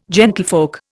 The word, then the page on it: gentlefolk: Indian